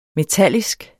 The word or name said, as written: metallisk